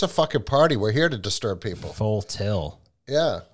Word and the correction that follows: full tilt: Full tilt.